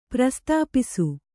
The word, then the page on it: ಪ್ರಸ್ಥಾಪಿಸು: prasthāpisu